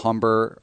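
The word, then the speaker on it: Humber: I have Braden, and then a guy I mentioned yesterday, Phil Umber. – H is silent.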